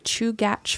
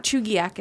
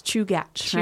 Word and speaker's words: Chugach: Did I say that right? Chugach? – Yep. – It's not Chugiak, it's Chugach, right? – Chugach, yeah. Like the mountains.